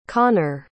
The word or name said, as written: konnor